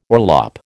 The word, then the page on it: orlop: American English